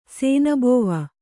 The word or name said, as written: ಶಾನಬಾವ